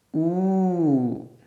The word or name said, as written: ū